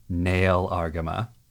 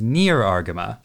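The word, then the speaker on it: Nahel Argama: Well, maybe we should throw a rare bone to the dub fans out there and use the dub pronunciation Nail Argama. But for those of you listening at home, every time we say Nail Argama, I want you to think that means near Argama.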